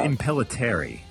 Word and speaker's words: Impellitteri: Yeah, I think it's Impellitteri. – I think so. – Yeah. I think that's it.